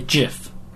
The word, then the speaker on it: GIF: Sorry, GIF. – That's how it's pronounced.